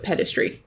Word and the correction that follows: pederasty: Pederasty? – Pederasty. That's the word. I can't ever say it properly.